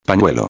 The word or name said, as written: pañuelo